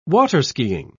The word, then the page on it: water-skiing: British English